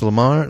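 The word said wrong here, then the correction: Laemmle: Theater. L A E Lamar. Oh shit. – Lemley. – No. Is it Lemley? Oh Lemley. My god! I sound like such an illiterate. Lemley Theater.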